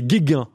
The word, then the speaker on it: Guéguen: On, prendra quelques exemples et pardonnez-moi, j'ai écorché votre nom. C'est Guéguin, hein, Jonathan Guéguin.